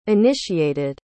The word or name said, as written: initiated